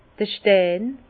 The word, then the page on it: d'r Stein: Alsatian; Bas-Rhin; Herrlisheim